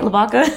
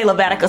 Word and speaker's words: Lavaca: I think I'm saying that right. Lavaca. I wanted to say Lavatica so bad.